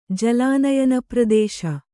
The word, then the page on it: ಜಲಾನಯನಪ್ರದೇಶ: Kannada